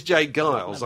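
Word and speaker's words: J. Geils: And obviously he didn't know whether it was Jay Giles or Jay Giles. – Oh, right. – It is Jay Giles. I know this for a fact because I met John Giles, as he's called.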